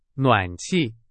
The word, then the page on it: 暖气: Chinese